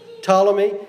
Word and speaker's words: Ptolemy: Not his descendants, not according to his authority, I've listed those generals out: Cassander, Ptolemy, the P is silent, Ptolemy, Antigonus, and Seleucus.